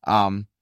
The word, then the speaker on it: hilasterion: That word is a Greek word, and I want to pronounce it for us the correct way. Hilasterion, okay, is how you pronounce that in Greek.